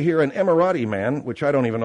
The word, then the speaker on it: Emirati: Oh, let me look at it. – Don't put it in my face. Read it over there. – Yeah, Emirati. – All right, Emirati. I guess you pronounce it like it's a car.